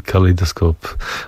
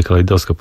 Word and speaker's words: Kaleidoskop: Zespół Hush Cookie ze swojej najnowszej epki Kaleidoskop. Właściwie Kaleidoskop powinien po polsku powiedzieć, a nie z angielska, akcentując.